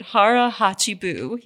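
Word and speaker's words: hara hachi bu: But I do want to mention one thing that Harihachibu, is that how you pronounce it? – I wrote it down so that I would pronounce it right. Harihachibu, yes.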